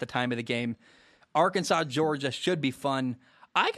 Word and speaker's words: Arkansas: It's spelled Arkansas, but I have to spell S A W at the end because I forget how to say it. I want to say Arkansas all the time.